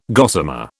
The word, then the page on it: gossamer: British English